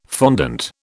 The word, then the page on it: fondant: British English